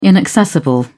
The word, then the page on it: inaccessible: British English